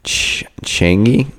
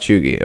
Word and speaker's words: cheugy: Changi. – How's it spelled? – Cheugy. Chugi. It's pronounced Chugi.